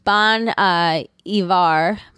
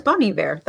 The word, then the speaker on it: Bon Iver: Please be aware that I didn't know any of this until looking it up, but Bon Iver. It's Bone. – That's my... Oh, I thought it was Bon Iver. That was always my understanding of the pronunciation.